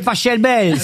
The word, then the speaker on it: Pachelbel: Canelloni, c'est Pachelbel. – S'il est allemand, ça se dit Pachelbel.